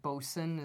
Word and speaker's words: boatswain: It's one of those boat words, like bosun is spelled boatswain.